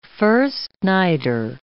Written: first-nighter

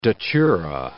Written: datura

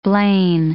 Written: Blain

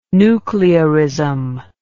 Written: nuclearism

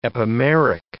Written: epimeric

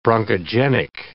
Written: bronchogenic

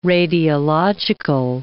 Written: radiological

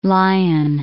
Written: Lyon

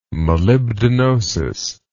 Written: molybdenosis

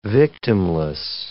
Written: victimless

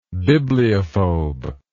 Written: bibliophobia

